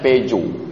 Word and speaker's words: Peugeot: Tulisannya Peugeot, membacanya Peugeot. Bahasa Perancis tulisan yang paling kacau.